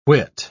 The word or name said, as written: whit